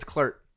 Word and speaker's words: Klrt: And it was like 100 different languages, and all of them are just like K-L-R-T. And I was like, that's not Clurt. What? It's Clurt.